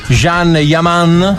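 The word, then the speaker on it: Can Yaman: Eh sì, perché Kanjaman è furioso su Instagram per la finta paparazzata. – Ecco. – Okay. – Tanto arriveranno adesso, non si dice così, si dice Jean Yaman.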